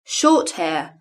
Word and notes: short hair: British English

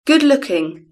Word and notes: good looking: British English

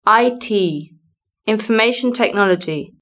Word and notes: IT information technology: British English

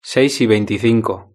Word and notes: seis y veinticinco: Spain Spanish

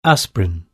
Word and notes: aspirin: British English

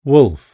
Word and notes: wolf: British English

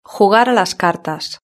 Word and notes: jugar a las cartas: Spain Spanish

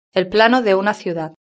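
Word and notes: el plano de una ciudad: Spain

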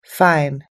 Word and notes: fine: American English

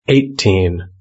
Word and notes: eighteen: British English

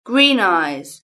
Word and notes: green eyes: British English

